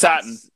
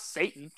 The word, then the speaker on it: Satin: It's Satin. Satin. Satin. – I'm about to say, it's not Satan.